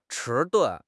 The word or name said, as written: 迟钝